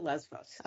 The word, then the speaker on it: Lesbos: I definitely remember it because I have been to Lesbos. Is that how you pronounce it? – Lesbos? Yes, you can pronounce it either way. Pronounce it Lesbos.